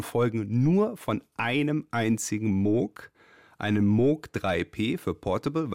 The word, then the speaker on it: Moog: In Great Britain, English-speaking countries, people see the double O and they say Moog. I just happen to like the sound of Moog better than Moog.